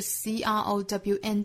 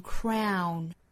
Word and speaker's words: crown: 最后呢，就是 crown 这个词，读成 crown, 不要读成 crown, k 和 r 之间不要拖音，辅音连缀发 cr, 再加 l, crow, 再去加鼻音发 crown.